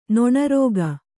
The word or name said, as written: ನೊಣರೋಗ